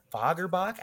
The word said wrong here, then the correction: Fagerbakke: I think it's Fagerbach is how it's pronounced. – don't know. – Fagerbakke is what it is. Fagerbakke.